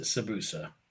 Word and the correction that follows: Tsubasa: Tsubasa. I'm sorry. – Tsubasa.